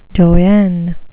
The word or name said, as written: doyenne